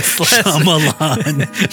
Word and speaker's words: Shyamalan: How do you say it? Shyamalan.